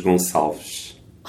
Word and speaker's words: Gonçalves: My name is Carlos Gonçalves.